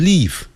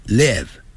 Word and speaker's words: live: I live 就是生活，这个不要念成 live，不是 live，要短的元音 ed live. I live together 不要念成 together，要念 together together。那个 T-O-G-E-T-H-E-R 有个 R 结尾，所以在美式的发音呢要卷舌的。